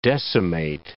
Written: decimate

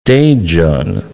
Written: Daejeon

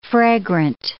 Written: fragrant

